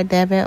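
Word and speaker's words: Devyn: Also, you can follow me on the Pursuit to Help Heal by Devin on Instagram.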